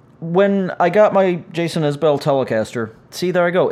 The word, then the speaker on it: Isbell: His name's pronounced Isbell.